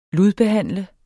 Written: ludbehandle

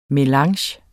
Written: melange

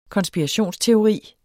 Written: konspirationsteori

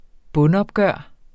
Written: bundopgør

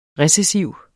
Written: recessiv

